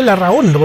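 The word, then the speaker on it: Larrahondo: Sí, es como es, cuando estamos en Chile, Larrajondo, porque la H es mudita, no se pronuncia. Larrajondo, no es Larrondo, es Larrajondo.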